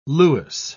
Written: lewis

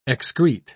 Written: excrete

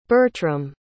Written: Bertram

